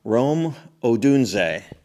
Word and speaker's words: Rome Odunze: Rome Odunze. – Yes, that is correct.